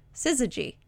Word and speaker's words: syzygy: Syzygy.